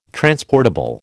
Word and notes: transportable: American English

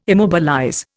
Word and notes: immobilize: Indian

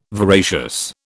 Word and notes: voracious: British English

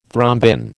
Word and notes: thrombin: American English

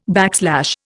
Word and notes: backslash: Indian

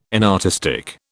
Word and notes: inartistic: British English

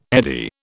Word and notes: eddy: British English

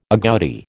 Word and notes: agouti: American English

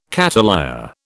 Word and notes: cattleya: British English

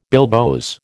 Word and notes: bilboes: American English

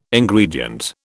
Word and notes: Ingredient: British English

